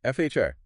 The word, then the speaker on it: FHIR: FHIR, as in building a campfire.